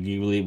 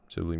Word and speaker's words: Ghibli: By the way, Ghibli, not Ghibli. – Dana says it could be pronounced either way because Miyazaki loves Italian stuff, and in Italy, he definitely does. Yeah. – And in Italy, they say it's a Ghibli.